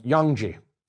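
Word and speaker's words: Yanqi: They were based on the great caravan cities, Khotan, Kucha, Kashgar, and Yangji, to pronounce it in its Chinese form.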